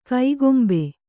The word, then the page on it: ಕೈಗೊಂಬೆ: kaigombe